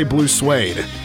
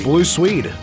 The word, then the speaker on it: Blue Swede: So the first use of the chant in the song was not Blue Swede. I wanted to make sure I got the name right again. Blue Swede. I want to say Blue Suede.